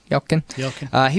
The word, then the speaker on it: Yelchin: How do you say it? – Yelkin.